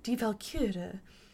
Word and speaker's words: Die Walküre: And to make me smile, he said, just think of like a kitten holding a spear, Die Walküre. And I thought, I don't know how that's supposed to help me. – Die Walküre.